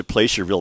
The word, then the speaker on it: Placerville: I'm going to pronounce it Placerville because I think placer is the type of mining, and you would pronounce that placer mining.